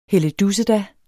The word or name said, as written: helledusseda